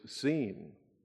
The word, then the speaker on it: Sin: It looks like it's spilled like sin. If you had that thought, you'd be correct, because there will be sin that will happen in this desert land by the Israelites. But the Hebrew pronunciation of this word is seen.